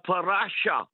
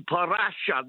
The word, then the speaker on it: parasha: And I want to pick his mind just 1 second here about the parasha. I learned okay. How to say that word because of you, parasha. That's how you say it.